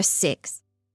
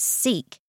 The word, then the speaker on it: Sikh: The Gokul family were Sikhs, an Indian religion founded in the 15th century. In a previous episode, I incorrectly pronounced it as Sikh, but the correct pronunciation is actually Sikh.